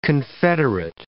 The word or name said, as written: confederate